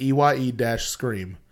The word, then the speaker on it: Eye-Scream: Okay, so it's spelled Ice Cream, like EYE-Scream.